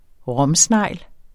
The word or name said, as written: ROMSNEGLEN